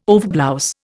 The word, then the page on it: overblouse: Indian